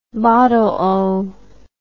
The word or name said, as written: bottleo